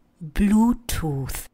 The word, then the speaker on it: Bluetooth: Zapomeňte na počištěnou výslovnost Bluetooth. Žádný tooth to není, ale je to tooth, což znamená zub. Další dvě slovička zadarmo. Bluetooth, modrý zub. Opakujte. Blue. Tooth. Tooth, jako thank you.